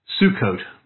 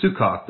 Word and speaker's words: Sukkot: And you'll hear some people who have become accustomed to the Hebrew word, and they'll call it, they'll say, are you celebrating Sukkot or Sukkoth, depending on what pronunciation they want to use.